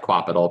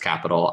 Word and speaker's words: Qapital: It's spelled Q-A-P-I-T-A-L. It looks like Quapital, but it's pronounced Capital.